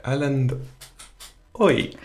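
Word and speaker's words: Erlend Øye: Yeah, so up next we have Like Gold by Erlend Oy.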